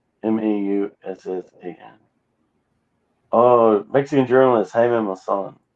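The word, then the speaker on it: Maussan: Masan, Hyman Masan, but yeah, he's known in Mexico.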